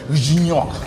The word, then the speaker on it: Gignac: Y el señor André Pierre Gignac. – Ya lo pronuncié bien, güey. – Muy bien. – Estaba así diciendo Gignac. – Gignac. – Como decimos todos.